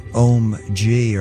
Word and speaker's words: Ohm-G: And before that, Om G, or I think that's how it's said, spelled OHM-G.